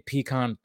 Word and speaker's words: pecan: You said pecan. Oh, I would have to slap you across the internet just for the way you pronounced that word. – Oh, did I say it wrong? – Well, you didn't say it wrong. You just didn't say it my preferred way. – Oh, gotcha. Which preferred way? – I say pecan.